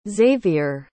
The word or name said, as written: xzavier